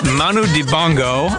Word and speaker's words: Manu Dibango: And Cameroonian saxophonist Manu Dibango, I think I pronounced that right. – I think it's yeah.